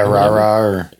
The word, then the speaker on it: Rara: And they call it, was it Rara music? Is that how you say it? – Yeah, Rara.